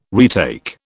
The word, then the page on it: retake: British English